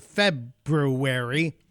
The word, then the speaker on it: February: It's also the Ides of February. I have to say it like that because some people get mad when I don't pronounce February right. So I have to say February.